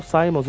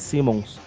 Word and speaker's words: Simmons: Eu, passei, eu, eu, pensei anos achando que o Spawn chamava o Simons, e descobri que é Simmons.